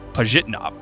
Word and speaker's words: Pajitnov: I talked about the Tetris movie on Friday and Tetris's creator, and it sounds like I didn't say his last name correctly. I have been writing his name for years, but rarely do I say it out loud. Apparently, it's pronounced Pajitnov.